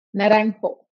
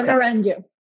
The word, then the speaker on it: Naranjo: Naranjo. Okay. Or Naranjo.